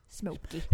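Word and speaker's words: Smokie: Jag vet inte om jag uttalar det rätt, men Smoking. – Living Next Door to Alice med Smoking. Smoky. – Smoky. – Ja, just det.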